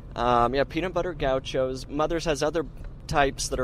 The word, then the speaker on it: Gaucho: Peanut Butter Gauchos. – Gauchos? – I don't know if I'm pronouncing that correctly. – Yeah, you are. Gaucho's correct.